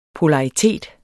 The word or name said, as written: polaritet